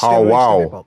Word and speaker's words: Paul Wall: Paul Wall. You got to say it properly. Paul Wall.